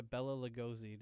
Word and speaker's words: Bela Lugosi: But in this movie, Edward is trying to make a name for himself, and he runs into, by accident, he runs into Bela Lugosi.